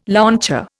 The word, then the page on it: launcher: Indian